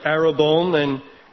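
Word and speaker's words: arabon (ἀρραβών): It's arabon, and it's spelled, it's pronounced in the Greek like arabon, And you can go back into the Old Testament and look up that word.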